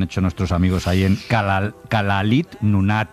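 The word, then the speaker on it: Kalaallit Nunaat: Nada, allí cuando juntan las dos L's le ponen una S y una L. Kalalit Nunaat. – Igual que lo he dicho yo. Kalalit Nunaat.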